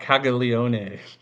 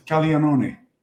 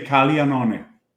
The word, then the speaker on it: Caglione: It's not Caglione. – It's Caglione. – Is it? Have I been saying it wrong? – In Italian, you say Caglione. – Oh, I forgot you just played baseball in Italy. So fun fact for everybody. GL and I in Italian is like a emphasized L with a slight accent on the G. So it's Caglione.